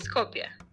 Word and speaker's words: Skopje: It's Skopje.